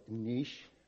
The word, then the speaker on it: niche: our niche, or niche, as it's supposed to be pronounced French-wise, I guess.